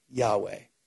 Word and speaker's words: Yahweh: The Hebrew phrase of I am in, pronounced is Yahweh. It symbolizes YHWH because ancient Hebrew didn't have vowels.